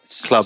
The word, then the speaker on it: Clwb: And Enzo, you'll have to tell me, is it pronounced just Club Flamingo? – Because it's spelling, which is Welsh.